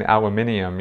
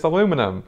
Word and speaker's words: aluminum: That's, to me, it was like, you know, the British and saying aluminium. You know, it's like, no, dude, it's aluminum.